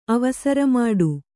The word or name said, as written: ಅವಸರಮಾಡು